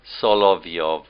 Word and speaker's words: Solovyov: Solovyov, I think is the way you pronounce it.